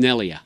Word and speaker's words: Neglia: Jim, is it Neglia, or is you said the G is silent? Is it Nelia? – It's Nelia.